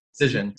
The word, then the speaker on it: Cision: And the Trendkite acquisition, it was 225 million by Cision. Is that how you pronounce it? – Cision. – Cision. – Although it's Swedish, so maybe you're probably closer to the actual pronunciation, but Cision.